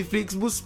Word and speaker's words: Flixbus: Stavamo parlando di Flixbus, però questa volta l'ho detto giusto.